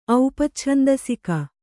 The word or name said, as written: ಔಪಚ್ಛಂದಸಿಕ